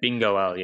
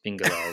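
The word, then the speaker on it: Bingoal: Bingwall, yes. Bingwall is the pronunciation.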